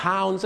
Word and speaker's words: pounds: pounds นั่นเองครับก็คือปอนด์นะฮะหนักกี่ปอนด์ใช่ไหมฮะซึ่งอย่าลืมนะครับห้ามออกเสียงว่าปอนด์นะฮะต้องเป็น pounds ครับ